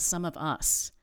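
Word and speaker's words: SumOfUs: There's a nonprofit called Some of Us, and it's spelled S-U-M of Us.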